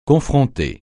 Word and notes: confronter: francais

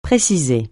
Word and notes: préciser: francais

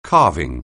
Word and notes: carving: anglais

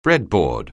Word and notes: breadboard: anglais

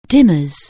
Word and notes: dimmers: anglais

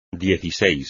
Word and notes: dieciséis: espagnol

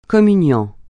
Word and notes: communiant: francais

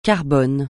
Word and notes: carbone: francais

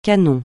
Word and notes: canon: francais

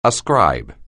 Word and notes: ascribe: anglais

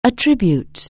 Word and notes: attribute: anglais

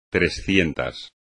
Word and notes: trescientos: espagnol

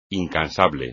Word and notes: incansable: espagnol

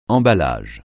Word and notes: emballage: francais

